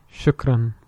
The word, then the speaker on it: shukran: Shukran. – Shukran. – Shukran. Shukran. Yeah, that. – that you gotta you roll get, the R. roll that R.